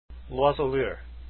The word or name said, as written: loiseau lyre